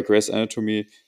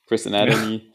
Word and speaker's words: Grey's Anatomy: Also bei Grey's Anatomy. – Jetzt hast du es falsch ausgesprochen. – Nein, ich hab die. Ja, stimmt. Scheiße. Ja, weil du mich da jetzt gefixt hast. Grey's Anatomy.